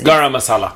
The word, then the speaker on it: garam masala: Garam masala? Is that what it is? – Garam masala.